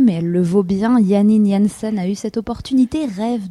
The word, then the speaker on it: Janine Jansen: On commence la semaine avec l'histoire de la violoniste néerlandaise Janine Jansen, si on le prononce justement à la hollandaise.